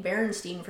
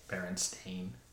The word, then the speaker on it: Berenstain: and saying Berenstein for some reason is easier than Bear. Like, it's a weird one too. It's like a Berenstain. Berenstain? How do you say it? – Berenstain. – See, Berenstain.